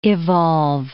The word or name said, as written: evolve